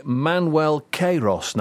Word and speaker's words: Manuel Queiroz: We're joined by Manuel Queiroz now.